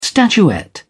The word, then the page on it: statuette: British English